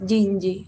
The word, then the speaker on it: Ginji: Y bueno, aclarar que es estudio Ginji. Ay, perdón. no, está bien. Como está escrito ahí medio raro, queda una confusión de cómo se pronuncia, pero es Jinji.